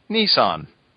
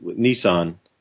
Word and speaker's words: Nissan: Well, see, you say it more like I say it, Rich, with Nissan. But Marcanix says it with the hard I, the Nissan.